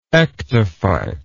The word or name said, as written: ectophytic